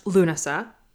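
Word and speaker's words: Lughnasa: It's pronounced Lunasa, from what I understand. – You are correct.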